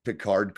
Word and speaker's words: Picard: I like that Vadic pronounces Picard correctly with no D.